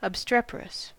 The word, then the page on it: obstreperous: American English